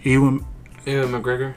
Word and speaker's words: Ewan McGregor: Ewan McGregor.